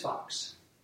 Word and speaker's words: Fox: My name's Fox.